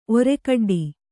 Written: ಒರೆಕಡ್ಡಿ

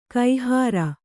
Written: ಕೈಹಾರೆ

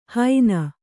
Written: ಹೈನ

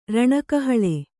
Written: ರಣಕಹಳೆ

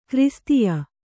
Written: ಕ್ರಿಸ್ತೀಯ

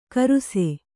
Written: ಖರ್ಸಿ

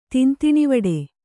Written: ತಿಂತಿಣಿವಡೆ

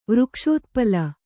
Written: ವೃಕ್ಷೋತ್ಪಲ

